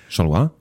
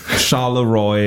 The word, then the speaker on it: Charleroi: Do I pronounce it right? Charleroi? – Probably for the French, yes. – For the French, but how would the Americans pronounce it? – Oh, it's embarrassing to say in English because it sounds. We say Charleroi. – Charleroi. Well, it's called Charleroi.